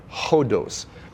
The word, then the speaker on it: hodos: Now the word way or road, kind of synonyms, they come from the Greek word hodos. Now it's spelled O-D-O-S, I mean, in our English version, but it's pronounced with a H, an aspirant, hodos.